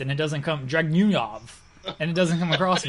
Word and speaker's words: Dragunov: We've got Walter and Ilya Dragunov. Is that how you say it? – Yes. – Okay. – He's Dragunov.